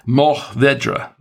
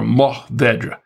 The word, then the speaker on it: Mourvèdre: It's actually pronounced Mourvèdre. That's a... Or Mourvèdre. That's how they pronounce it in France.